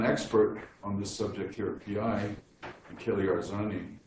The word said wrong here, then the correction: Arzano: If I pronounced it correctly, Arzano, Achille Arzano.